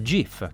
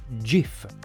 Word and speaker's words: GIF: Secondo la documentazione tecnica ufficiale rilasciata nel 1987, la pronuncia corretta sarebbe GIF.